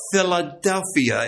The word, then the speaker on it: philadelphia: Philadelphia is how you pronounce transliterally the Greek term.